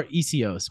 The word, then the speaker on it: Essihos: So it's like, it's more Essihos, but okay, that's fine.